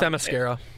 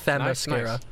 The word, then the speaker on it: Themyscira: Themyscira. – Nice. – And then sounds like you're saying the mascara. – Yeah, that's literally what it's basically spelled like. It's Themyscira, yeah.